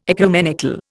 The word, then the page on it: ecumenical: Indian